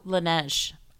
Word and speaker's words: Laneige: I have to shout this out with, and I'm going to pronounce it wrong Langley. – oh, Laneige.